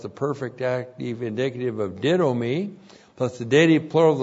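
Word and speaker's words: didomi (δίδωμι): D-I-D, long O-M-I, didomi. Didomi is the verb to give.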